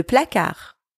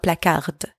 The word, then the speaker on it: placard: Le placard. Closet. Le placard. Again here, don't pronounce the D at the end. It's not placard. Placard.